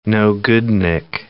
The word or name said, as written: no-goodnik